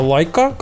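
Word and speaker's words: Leica: Знаете, такой, типа, прикольный ролик э, рекламный э, компании Лайка, она же Лейка, Лейка да. в простонародье. Вот. А это как она правильно, кстати, по-английски читается? – Правильно Лайка. лайка.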